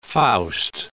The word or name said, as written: Faust